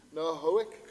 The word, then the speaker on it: Noahic: Then you've got the, how do you know how you pronounce that? Nahoric covenant, Noahic covenant.